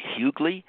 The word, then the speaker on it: Huguely: Huguely.